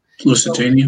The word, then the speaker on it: Lusitania: how do you say it? Lusitania? – Lusitania.